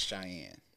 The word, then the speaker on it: Cheyenne: So growing up, my name is Cheyenne. – How you spelled it? – C-H-E-Y-E-N-N-E.